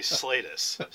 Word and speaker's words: Slatus: And I don't often correct people, but it's actually Slatus. So we've got three different pronunciations. I've heard them all since I was about 2 years old.